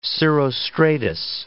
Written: cirrostratus

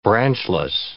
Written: branchless

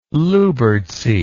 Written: lyubertsy

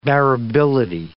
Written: bearability

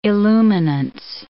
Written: illuminance

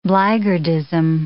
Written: blackguardism

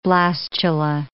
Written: blastula

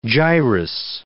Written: gyrus

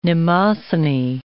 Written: Mnemosyne

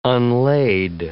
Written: unlade

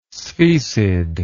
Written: sphecid

